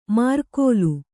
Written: ಮಾರ್ಕೋಲು

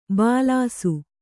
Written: ಭುಲ್ಲೈಸು

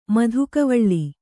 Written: ಮಧುಕವಳ್ಳಿ

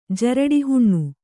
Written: ಜರಡಿಹುಣ್ಣು

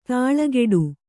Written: ದಳೆಕಟ್ಟು

